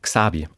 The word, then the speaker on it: Xabi: Die heißt Xabi, also Spanisch, einfach nur deutsch ausgesprochen, X-A-B-I geschrieben, ein Mädchen, mittlerweile acht Jahre alt.